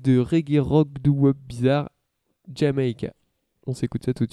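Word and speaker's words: D'yer Mak'er: Alors prenez vos calepins, parce que je vais vous les appeler, ça s'écrit D'YER espace MAK'ER et ça se prononce Jamaica.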